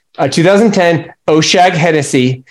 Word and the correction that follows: O'Shaughnessy: Hell Mountain Cabernet Sauvignon for $95. – No, you know, it's funny. I was gonna mention the O'Shaughnessy wines because Oshag Hennessy.